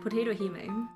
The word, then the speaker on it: Potatohime: Potatohime.